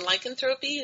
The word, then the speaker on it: lycanthropy: Lycanthropy, is that what it is? – Yes.